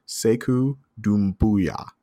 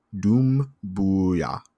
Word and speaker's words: Doumbouya: Sekou Doumbouya. Doumbouya. I hear the Bouya is like, you're supposed to like mash that together. And like, that's the way it's supposed to go.